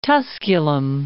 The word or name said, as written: Tusculum